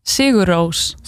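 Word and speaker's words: Sigur Rós: En wat dan ook belangrijk is om te benoemen, nu we toch even over uitspraak hebben, hoe spreek je Sigur Ros uit? – Sigur Roos. – Sigur Roos. – Sigur, het is een E. Ja. Sigur. – Sigur. Roos. – Roos. Ja. Als Jan Roos.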